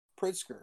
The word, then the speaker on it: Pritzker: Pritzker, I think that's how you say his name.